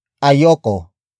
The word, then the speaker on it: ayoko: Word for word, it's ayoko ng kanin. Ayoko ng kanin. Ayoko ng kanin. The first word is ayoko. Ayoko. Ayoko. Ayoko is spelled as A-Y-O-K-O.